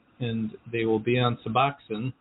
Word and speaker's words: Suboxone: Suboxone.